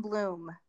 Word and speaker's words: Bluhm: It my last name is Bloom, but it is spelled differently. It's BLUHM, life in bloom.